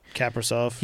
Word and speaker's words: Kaprizov: Is it Kaprizov? Is that how you say it? That rookie? – Kaprizov.